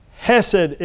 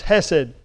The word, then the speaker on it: chesed: Hesed is the word. It's spelled C-H-E-S-E-D. The Hebrew word is hesed.